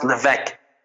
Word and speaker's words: Levesque: It's pronounced Levesque, but it's a French name.